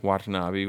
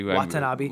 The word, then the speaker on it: Watanabe: Watanabe. – Watanabe.